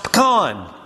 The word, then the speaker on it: pecan: Or you have people that refuse to admit that it's pronounced pecan, when that's obviously how it's properly pronounced.